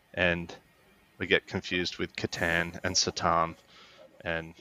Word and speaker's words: Catan: And they were still talking about the Satan or Catan and how they betray. – Yeah, what do you think the correct pronunciation of that is? They call it the Catan. – Catan. Yeah, it's Catan.